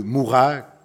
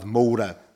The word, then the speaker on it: Moura: Contudo, ocorreu recentemente na localidade de Mourá, que se escreve como a nossa localidade Moura, mas se diz Mourá,